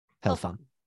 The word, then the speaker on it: Helfan: H-E-L-F-A-N. Helfon.